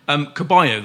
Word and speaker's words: Ceballos: Caballo, then, as is the proper pronunciation, spelt Ceballos.